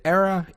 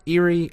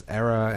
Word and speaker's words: Eira: Is it Era, Erie? I've heard it pronounced both ways, but it actually is just the Battle for Ireland. – Oh, okay. – So I think the official... pronunciation is Era, and it's like I said, that's the official name that was for Ireland. – Is it like E-I-R-A? Is that it? – Yeah.